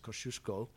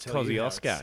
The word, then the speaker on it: Kosciuszko: Which we established on the drive here probably would best be pronounced as Kosciuszko. – Kosciuszko. – As a Polish thing, but as Australians, we don't care for the traditional pronunciations. – No, mate, Kosciuszko.